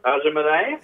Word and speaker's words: Aja Monet: I think, how do you say their name? Aja Monet? – Ajumane. – Yeah. Boom.